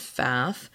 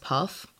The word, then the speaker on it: Pfaff: His last name is spelled P-F-A-F-F, and I have been pronouncing it Faff. What I learned recently is that it's pronounced Puff, which is not what I expected, because I don't see a U or there's that extra F in it.